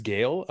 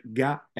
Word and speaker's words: Gael: Is it pronounced Gale? I kind of read the name, but it's always a hard name to pronounce. It's like Gael.